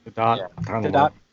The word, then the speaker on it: Gadot: Gadot. I think she says you pronounce it the T.